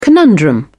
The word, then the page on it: conundrum: British English